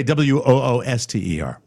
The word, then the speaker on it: Worcester: Please stop with the woobity because you're too fucking lazy to actually figure out the pronunciation of Worcester, as in Worcestershire, as in Worcester, Massachusetts. It's Worcester. That's the phonetic pronunciation. – There's a big fat redneck. – I should say Wooster.